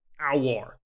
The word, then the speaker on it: Aouar: Yeah, yeah, and I think it's Aurar. I'm gonna need to learn how to say his name. He's got too many vowels in a row. – Aouar.